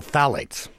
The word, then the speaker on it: phthalates: Yeah, this is an incredible substance called phthalates.